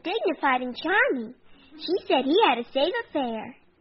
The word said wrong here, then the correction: savoir faire: Oh, that's wrong, honey. The word is pronounced savoir faire.